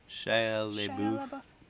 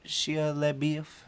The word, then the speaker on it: Shia LaBeouf: Shia LaBeouf. – Yeah, and so his name is Beef, but it's spelled wrong.